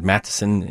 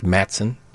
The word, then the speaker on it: Matteson: The governor of Illinois at the time, Matteson, M-A-T-T-E-S-O-N, maybe two S's in there. He founded Matteson, Illinois, which many pronounce Matson. It's Matteson.